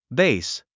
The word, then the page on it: bass: béis; ベイス